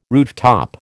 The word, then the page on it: rooftop: American English